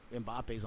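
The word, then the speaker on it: Mbappé: Mbappe, I think it's how you pronounce it,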